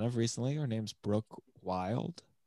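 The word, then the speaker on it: Wylde: Yeah, but you don't pronounce the E, Nick. – No, I'm going to call her Wilde.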